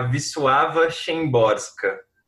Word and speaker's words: Wisława Szymborska: que é a Wisława Szymborska é, essa poeta, ela é impressionante porque ela traduziu em versos, ela escreveu versos, né?